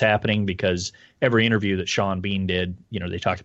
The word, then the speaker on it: Sean Bean: Also, Sean Bean's name should be pronounced the same, the first and last, so it should be Sean Bean.